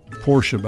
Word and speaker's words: Porsche: Porsche, by the way, is the correct pronunciation.